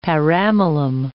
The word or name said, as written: paramylum